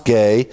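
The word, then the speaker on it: gay (γῆ): We have the Greek word there, gay, G-E, is the spelling, pronounced gay.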